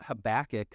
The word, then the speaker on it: Habakkuk: So I thought Habakkuk, because we're from the Midwest, like back, and we have that nasally A.